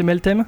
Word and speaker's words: Meltem: Est-ce que je le prononce bien C'est Meltem